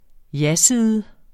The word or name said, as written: jaside